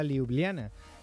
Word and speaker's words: Ljubljana: Concretamente a Ljubljana.